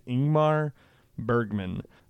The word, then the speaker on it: Ingmar Bergman: and that is Ingmar Bergman. I said it right this time. I've been having a hard time saying his name. It's Ingmar Bergman.